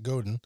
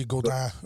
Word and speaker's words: Godin: Most people pronounce it Godin. – That's how I pronounce it. So it's Godin. – It's French, yeah. So it's supposed to be Godin, but just Godin's fine. That's what everybody's used to hearing.